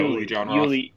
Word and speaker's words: Uli John Roth: So, my friend was telling me that Uli, am I saying that right? – Yeah, Uli John Roth.